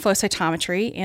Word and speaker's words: flow cytometry: And I was supposed to be doing flow cytometry, and I fell into this microscope. – Wait, you keep throwing syllables here. Photocytometry. – Flow cytometry. Flow cytometry.